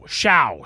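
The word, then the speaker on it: Shough: I think it's S-L-O-U-G-H. – It's show. – Show. Shao. Shao. Show. – Big whatever. – Show. – I've heard his name pronounced. I think it's Tyler Shao, but I'm not 100% sure.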